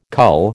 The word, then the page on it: cull: American English